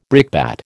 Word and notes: brickbat: American English

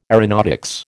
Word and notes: aeronautics: American English

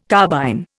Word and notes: carbine: Indian

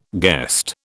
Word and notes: guest: British English

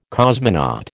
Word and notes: cosmonaut: American English

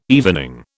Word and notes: evening: British English

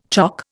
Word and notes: chock: Indian

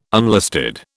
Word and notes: unlisted: British English

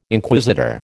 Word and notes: Inquisitor: American English

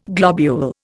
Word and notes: globule: Indian

